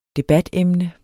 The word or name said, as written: debatemne